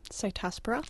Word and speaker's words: Cytospora: And I was told by an arborist that two of the trees had, and I don't know if I'm pronouncing this right, cystospora? – Cytospora. – Cytospora, yeah.